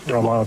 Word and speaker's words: Romano: Does a Ray Romano ever Romano, pronounced Romano? – Romano.